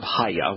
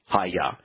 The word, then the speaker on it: haya: So if his name is based on the verb haya, which we know how to pronounce, haya, and haya has two hays in it, as does Yahweh's name, and one yod, as does Yahweh's name, and it's pronounced haya,